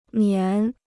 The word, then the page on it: 棉: mián